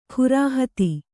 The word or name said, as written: ಖುರಾಹತಿ